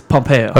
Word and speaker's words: Pompeo: Ron Popeil is replacing him? – Pompeo. Okay. Is that how you say it? – Yeah. – It's Pompeo.